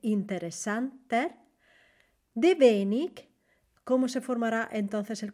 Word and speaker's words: wenig: Wenig Deutsch. Atención a la pronunciación aquí, wenig, que es poco, se escribe con W, E, N, I, G, y la W en alemán se pronuncia V, V.